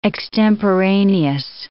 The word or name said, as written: extemporaneous